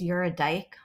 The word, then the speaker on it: Eurydike: In German, it's pronounced Eurydike, which I just thought was so funny.